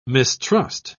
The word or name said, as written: mistrust